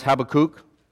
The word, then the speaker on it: Habakkuk: Um, so we're going to be looking, if, you, if you've navigated Habakkuk, we're going to look at it this week and next week. Um, by the way, it is, can also be pronounced Habakkuk, um, but his mother said, don't do that because then they're going to start calling him Kook for short.